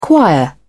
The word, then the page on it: choir: British English